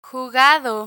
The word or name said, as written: jugado